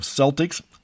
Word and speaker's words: Celtics: I've never understood the pronunciation of Celtics. Irish Gaelic always uses the hard K sound for the letter C.